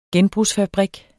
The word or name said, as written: genbrugsfabrik